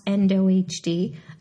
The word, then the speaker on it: NDOHD: That's NDOHD. It's pronounced EndoHD.